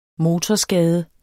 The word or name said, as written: motorskade